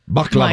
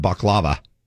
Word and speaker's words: baklava: Baklava. – My gosh, this is good. – And I've been saying it wrong for years. I've been calling it baklava. – Well, and it's baklava.